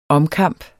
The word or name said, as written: omkamp